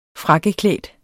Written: frakkeklædt

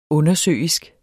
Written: undersøisk

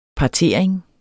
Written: partering

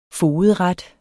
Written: fogedret

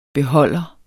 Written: beholder

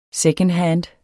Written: SECONDHAND

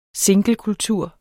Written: singlekultur

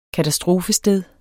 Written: katastrofested

